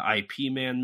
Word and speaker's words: Ip Man: IP Man movies and a ton IP of Man.